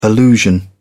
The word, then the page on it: allusion: British English